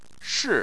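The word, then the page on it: shi: tone 4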